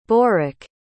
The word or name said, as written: borak